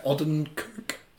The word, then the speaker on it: Odenkirk: Odenkirk.